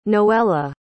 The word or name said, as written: noela